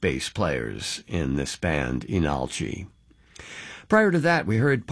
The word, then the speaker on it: Leenalchi: Again, it looks like Leenalchi, but pronounced Inalchi.